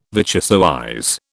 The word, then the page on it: vichyssoise: British English